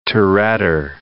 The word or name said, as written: Touretter